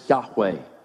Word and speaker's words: Yahweh: But behind that in Hebrew are the letters Y-H-W-H, which is the covenant name of God. His name, as best we know how to pronounce it, is Yahweh.